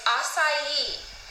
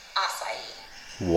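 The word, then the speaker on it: açaí: Well, it's acai, and it's a Brazilian superfruit. I love it. It's great. Definitely buy it. But when you go in, make sure to use the proper pronunciation, acai.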